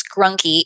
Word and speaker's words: Greunke: I don't use it very often because it is German and it's hard to pronounce and spell, but it is Grunke and it is not spelled the way it sounds.